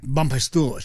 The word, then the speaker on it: Bompastour: Bompastour. She said, just say it with a French accent. You can't get it wrong.